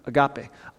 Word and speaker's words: agape: This word love in the Greek is the word agape or agape. Mexico has a different pronunciation, agape.